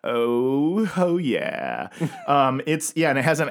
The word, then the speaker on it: Ooho: Oh, when I saw the way it was spelled, it's spelled O-O-H-O exclamation point. – I would say ooh-hoo. – Right. That's what I was saying as I was reading the articles. Yeah. I would say like ooh-ho. – Yeah. – But apparently it's called Ooho.